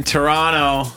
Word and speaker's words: Toronto: You're not supposed to pronounce the T, bro. – Tonight I'm in Toronto.